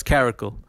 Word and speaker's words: caracal: Caracal or ca- I can't pronounce it, but caracal. No, it's caracal.